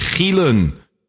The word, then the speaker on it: Gielen: My name is spelled G-I-E-L-E-N. That's the I before the E. My name is Chris Gielen. Gielen, not Gielen, which of course is being horny.